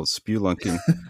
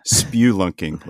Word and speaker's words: spelunking: Well, I've always heard it as spewlunking, like you're spewing something out of your mouth.